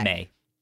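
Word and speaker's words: Mei: It's M-E-I. Is it May? – I believe that's okay. – May.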